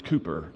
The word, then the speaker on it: Cowper: His name is spelled C-O-W-P-E-R, so I had been pronouncing it how? Exactly, Cowper. But the British, who gave us our English language, do not follow the normal rules of their own phonetic vocabulary, and it's pronounced Cooper, apparently, and he's right.